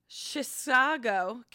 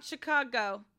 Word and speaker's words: Chisago: It's not Chicago. – Okay. – But if it's spelled that way, but the second C is an S. – It's Chisago. – So that's a long C? Is that a long C or a hard C? I forget that. – It's an S anyway.